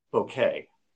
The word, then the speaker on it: bokeh: The answer is bokeh. Okay. So the reason why, and you can go to Wikipedia and look this up. Actually, Wikipedia shows both pronunciations, but the word is a Japanese word.